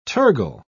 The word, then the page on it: tergal: British English